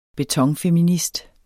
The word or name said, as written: betonfeminist